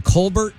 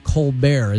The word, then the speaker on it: Colbert: And I don't remember when he did it, but his name is actually Colbert, and that's how it's pronounced. But I think maybe when he got that show, well, it was before then, I guess. He said, no, I'm going to pronounce it Colbert, as if that somehow is, you know, elevate.